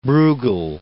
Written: Breughel

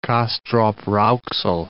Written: Castrop-Rauxel

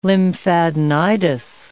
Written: lymphadenitis

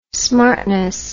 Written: smartness

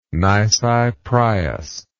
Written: nisi prius

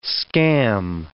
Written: scam

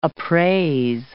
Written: appraise

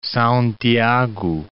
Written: Sao Tiago

